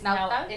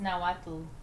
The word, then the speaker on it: Nahuatl: So chaneques comes from the Nahuatl word. I don't know if I'm saying that right. – It's Nahuatl. – It's Nahuatl. – Okay. Nahuatl.